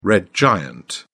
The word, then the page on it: red giant: British English